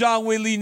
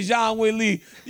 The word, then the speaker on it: Zhang Weili: Because you come up here and say Zhang Weili. Corporate Jake, you can't be doing this, man. You got to say it right if you on the God mic. Zhang Weili, man. Put some respect on the champ's name, Corporate Jake.